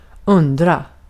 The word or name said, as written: undra